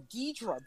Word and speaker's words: Ghidorah: New number 2, Ghidorah, or Ghidra. That's another pronunciation I've heard.